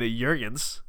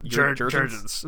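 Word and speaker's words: Juergens: How were we saying it last time? – Jurgens. It was terrible. I was going to hard J. I needed to go to a soft J, like yogging.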